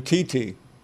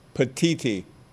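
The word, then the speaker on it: Petitti: But the new Big Ten commissioner, Tony Petty, did I say his name right? – Petiti. – Say it again. – Petiti.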